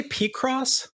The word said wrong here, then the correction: Picross: It definitely comes from pick, not P. – Yeah, it's not P.